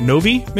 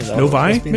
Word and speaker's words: Novi: They're also hosting a grand reopening event on Saturdays for their, anyone know how to pronounce that? Novi, Michigan? – Novi, Michigan? – Novi. You're asking me like I pronounced things correctly.